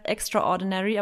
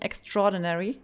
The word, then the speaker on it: extraordinary: Und das sind so typische Wörter, die man immer denkt, das heißt extraordinary, aber es heißt eigentlich korrekt ausgesprochen extraordinary.